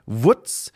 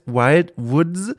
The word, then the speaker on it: Wild Woods: Äh, ich muss immer ein bisschen lachen, weil ich das so ausspreche mit meiner süddeutsch-fränkischen Art wie die Woods, die, das kleine Schweinchen. Aber es heißt Wild Woods, also die, die, die wilden äh, Wälder.